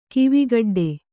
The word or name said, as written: ಕವೆಕಡ್ಡಿ